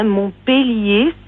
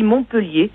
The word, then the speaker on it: Montpellier: Je voulais vous dire qu'on ne dit pas une peleu et que quand il y a un E devant deux L, on dit E. Alors c'est la faute commune à tous, hein. Montpellier, c'est la prononciation réelle, et tout le monde dit Montpellier, sauf les gens qui y vivent et qui en ont l'habitude.